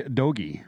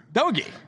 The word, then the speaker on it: Doge: I think its pronunciation is actually Doge. – Doge. – I think it's a Doge. It's a hard G.